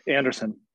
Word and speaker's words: Anderson: Anderson.